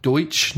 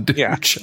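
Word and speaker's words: Deutch: Is that how you say her name? – Deutsch. – That makes much more sense than Deutsch.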